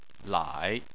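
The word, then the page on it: lai: tone 3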